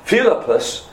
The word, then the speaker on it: Philippus: Philippus, if you put the emphasis on the right syllable, Dr. Ark would have corrected me there. Philippus.